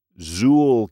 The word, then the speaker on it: ZU.13: Zuul Keymaster, although it's spelled as ZU.13, is a 1-strength decoder.